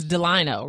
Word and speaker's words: Delino: And it's Delino, right? – Yes.